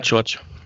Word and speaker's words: George: 5 ähm, hat Jan, wie wird es ausgesprochen? Jan George wahrscheinlich, ne? – Jan George.